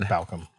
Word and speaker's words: Balchem: But the ball cam guy, is that how you say it? Ball cam. He said Balcom went to